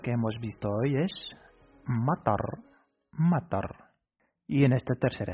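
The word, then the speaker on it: matar (مطر): Se pronuncia matar.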